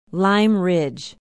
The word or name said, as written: lime ridge